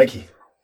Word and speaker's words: Nike: Altså mine børn, de kan jo godt komme op og slås om, hvorvidt det udtales Nike eller Nike. Uh, det er Nike.